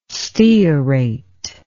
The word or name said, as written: stearate